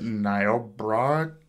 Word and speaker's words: Niobrara: I think it's pronounced Niobrara County. That's kind of a difficult county to pronounce.